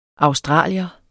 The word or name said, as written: australier